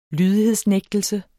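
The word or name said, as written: lydighedsnægtelse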